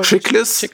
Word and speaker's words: Chiklis: Chiklis.